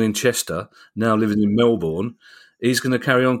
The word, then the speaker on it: Melbourne: And Marty Laidler, and I quote, says, "This is a question from a Sunderland supporter born in Middlesbrough, now living in Melbourne, pronounced Melbourne, not Melbourne."